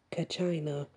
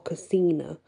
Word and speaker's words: Kachina: Now, Kachina is sometimes pronounced as Kachina or Kasina because it's K-A-C-H-I-N-A. And it depends which area of people you speak into on the pronunciation.